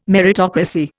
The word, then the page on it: meritocracy: Indian